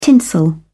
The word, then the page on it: tinsel: British English